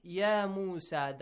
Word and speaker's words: يَا مُوسَى: So make sure you prolong a longer sound, okay? يَا مُوسَى. So three long vowel sounds.